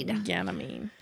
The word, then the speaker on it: Ganymede: Ganymede.